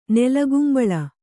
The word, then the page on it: ನೆಲಗುಂಬಳ: Kannada